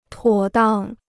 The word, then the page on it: 妥当: tuǒ dang